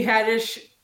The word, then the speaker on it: Haddish: I was just like, Tiffany Haddish rhymes with radish,